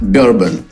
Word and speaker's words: bourbon: А оказывается, что о, вот в городе Герой Нью-Йорк произносят как Бербен.